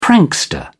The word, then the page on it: prankster: British English